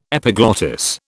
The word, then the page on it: epiglottis: British English